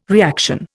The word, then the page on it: reaction: Indian